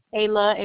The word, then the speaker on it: Ayla: Ayla, A-Y-L-A. – A-L-Y. – A-Y-L-A.